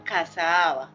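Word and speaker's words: Casa Ahava: También estamos con nuestra fanpage que se llama Casa Ava. Se escribe Casa Normal y luego A-H-A-V-A.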